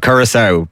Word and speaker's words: Curaçao: Germany versus how do you pronounce courage? – I've never got it right. Don't worry about Curaçao.